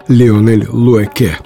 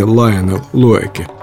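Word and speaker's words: Lionel Loueke: Поэтому правильно его имя произносить – Леонель Луэке, а не Лайонел Луэке.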